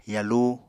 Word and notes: yalu: Apatani

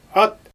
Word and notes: at: Xyzyl

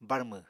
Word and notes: barmw: Apatani

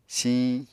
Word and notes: siñ: Apatani

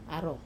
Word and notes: aro: Wamesa

